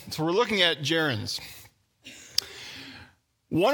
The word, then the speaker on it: gerunds: You look at that word, it should be gerunds. That's the first problem with grammar, is you have this soft G sound. It's gerunds.